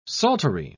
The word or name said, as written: psaltery